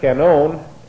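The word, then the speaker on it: canon (κανών): We're actually studying the C-A-N-O-N, and a cannon. The word canon actually comes from a Greek word that's a transliteration of this, K-A-N-O-N, canon, and K-A-N-O, long O-N here, canon.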